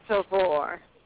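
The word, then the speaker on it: Tovor: My name is Tavor. – Tavor. Spell that for me, please. – It's T-O-V-O-R.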